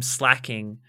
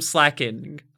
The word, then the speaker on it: Slaking: But what you might not have noticed about Slaking's name is that it is also a heteronym. A heteronym meaning a word that is spelt the same as another word but pronounced differently.